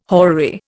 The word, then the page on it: horary: Indian